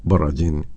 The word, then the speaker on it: Borodin: De Piotr Ilich Tchaikovsky se ha escuchado Cuarteto de Cuerdas en si bemol mayor por el Cuarteto de Cuerdas Borodin o Borodin en su pronunciación original.